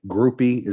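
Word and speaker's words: Grupe: I spoke with the two transfers, Brandon Joseph, the safety from Northwestern, and Blake Groupie is how it's pronounced,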